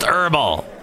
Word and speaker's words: herbal: All right, it's pronounced herbal. I know, but damn it, the H throws me.